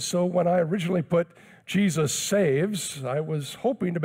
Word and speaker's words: Jesus: He goes by Jesse, but his legal name is spelled J E S U S, pronounced Jesus.